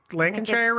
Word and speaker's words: Lancashire: And did I say that? Lancashire, right? – I think it's Lancashire. – Lancashire, thank you. I knew I said it wrong as soon as I did it.